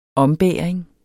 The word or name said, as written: ombæring